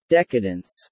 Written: decadence

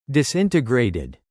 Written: disintegrated

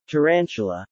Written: tarantula

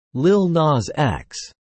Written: Lil Nas X

